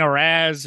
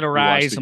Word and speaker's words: Arráez: And I've been saying Arraz and Arraz and all this stuff. So I finally said, fuck it. I got to see how to say this guy's name. Watched the game. They said Arise. – I'm like, okay. – I'm like, all right. It's Arise. It's Arise.